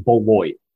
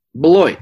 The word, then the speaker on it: Beloit: It's pronounced Beloit. Beloit. The Bo- the Beloit Snappers. There we go. So I think that's like the third time that they played Beloit this year. I think this is the first time we've actually pronounced it correctly.